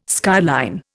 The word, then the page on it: skyline: Indian